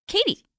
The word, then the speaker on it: Katie: So her name is K-A-T-Y. – Yep. – Mine is K-A-T-I-E. – So yours is like a Katie. – Katie. Hers and mine's is like K-T. – Well, I K-T-I.